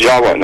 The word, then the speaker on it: Java: The word in Java, the island of Java, is pronounced Jawa, actually. Indonesians will pronounce it Jawa, not Java like we do.